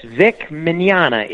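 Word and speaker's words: Mignogna: Okay, well, the Spanish word for tomorrow is mañana. – Mañana. – And so it's Vic Mañana. It's Italian, but it's pronounced like the Spanish tomorrow.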